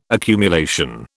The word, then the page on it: accumulation: British English